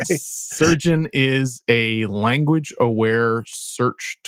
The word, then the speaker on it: SRGN: I've got a tool from Alex Povel called SRGN, pronounced surgeon.